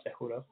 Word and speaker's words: Cejudo: Cejudo.